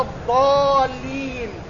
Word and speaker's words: الضالين: ضاء غلط، ولا الضالين غلط، ولا الضالين غلط، وإنما العرب يقولون ولا الضالين.